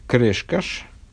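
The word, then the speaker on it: Крескас: Раби Хасдай, сын Раби Аврома Крешкаш. Вот как, оказывается, это произносится.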